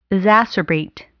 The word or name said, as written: exacerbate